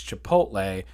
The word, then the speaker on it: Chipotle: She literally pronounces Chipotle. See, I was about to do it. It's Chipotle, but she pronounced Chipotle.